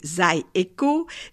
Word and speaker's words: Zydeco: C'est dans le sud de l'État de Louisiane, qui est en réalité la terre du Zydeco, le mot que l'on doit prononcer Zydeco, c'est une adaptation créole de l'expression les haricots, inspirée d'une vieille danse intitulée Les haricots sont pas salés.